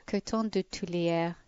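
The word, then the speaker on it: Coton de Tulear: This is Lori in Virginia, and she says, we're bringing home two, okay, coton de tulleur. – Oh, yeah. – How do you say it? You say it better. – Coton de tulleur.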